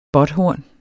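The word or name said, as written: BÅTHORN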